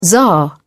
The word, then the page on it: tzar: British English